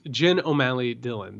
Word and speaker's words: Jen O'Malley Dillon: And by the way, just for the record, it's Jen O'Malley Dillon. That's correct. I mispronounced her name.